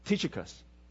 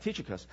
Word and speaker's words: Tychicus: Tychicus. All right, Tychicus.